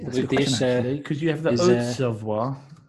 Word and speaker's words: Savoie: But, yeah, Savoie. – But you say Savoie, you'd never say Savoy.